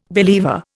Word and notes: believer: Indian

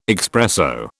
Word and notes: expresso: British English

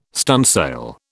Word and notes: stunsail: British English

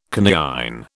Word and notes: coniine: British English

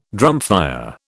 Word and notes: drumfire: British English

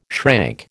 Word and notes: shrank: American English

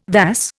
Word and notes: vas: Indian

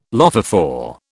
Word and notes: lophophore: British English

